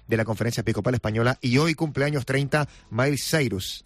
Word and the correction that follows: Miley: Sí, Miley Cyrus.